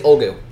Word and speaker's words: Ulgu: I think it's Ulgu. – Oh, Ulgu. – Ulgu, I believe. – U-L-G-U, yeah.